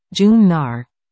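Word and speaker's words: Jón Gnarr: John Knarr, Icelandic pronunciation, June Knarr, is the exception.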